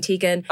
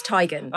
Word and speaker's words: Teigen: you've all been calling me Teigen. It's Teigen. – Oh. – It's Chrissy Teigen.